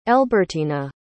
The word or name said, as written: elbertyna